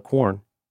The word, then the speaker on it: Quorn: And that's the pandemic times, or as we like to call it, corn, which I think is canonically spelled Q-U-O-R-N. – You got it. – Rhymes with corn.